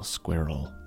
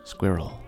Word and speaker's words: squirrel: It does have the R sound in the middle, squirrel, squirrel.